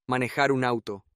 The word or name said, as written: manejar un auto